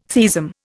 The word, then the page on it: theism: Indian